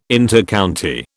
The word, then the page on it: Intercounty: British English